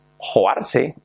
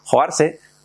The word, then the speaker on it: hoarse: And también tenemos la palabra fijaros, afónico, ronco, cuando alguien tiene la voz tomada, que se dice, se escribe joarse. Mira, ahora pasa a mí. Qué casualidad. No lo he hecho queriendo, ¿eh? I didn't do it on purpose. Se escribe joarse, H-O-A-R-S-E. Soy un poco malo deletreando. Joarse.